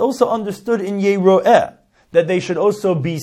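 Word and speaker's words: Yeroeh (יראה): Shenema, because it says in the pasuk Yira. Now, but we pronounce it as we said Yeroeh.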